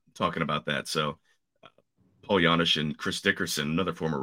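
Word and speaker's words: Janish: They have Paul Janisch, everyone's favorite soft J, everyone's favorite slick fielding red from early 2010s.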